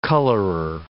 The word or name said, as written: colorer